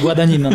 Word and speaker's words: Guadagnino: Nie, nie Guadagnino na pewno.